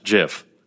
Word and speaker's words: GIF: He declared in 2013 that the acronym GIF should be pronounced JIF, which I don't care that he said that.